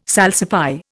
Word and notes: salsify: Indian

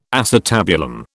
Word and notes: acetabulum: British English

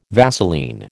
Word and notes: Vaseline: American English

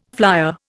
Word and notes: flyer: Indian

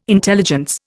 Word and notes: intelligence: Indian

